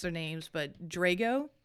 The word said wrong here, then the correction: Drogo: Khal Drogo.